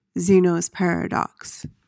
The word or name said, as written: Zeno's paradox